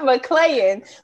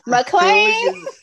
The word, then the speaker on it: McClain: Ooh, out here looking like China Ann McClain. Okay, look. – Not McClain. McClain.